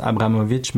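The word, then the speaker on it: Abramović: On croit que ça se prononce Abramovic, mais dans son nom, il n'y a, a pas de H, donc faites pas ah, de fausse.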